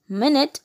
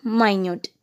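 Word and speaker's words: minute: it can be pronounced as மினிட் and also மைன்யூட்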